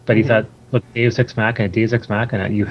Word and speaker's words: Deus Ex Machina: From Deus Ex Machina or Machina? – Oh, yeah. No, you got it right the first time. – Deus Ex Machina Games.